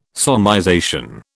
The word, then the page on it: solmization: British English